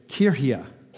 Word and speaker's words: Kirche: The German is spelt K-I-R-C-H-E, and so you can see where we get C-H-U-R-C-H kind of right from, but the actual pronunciation in German is Kirche. I'm going to try to get that right this time, Kirche.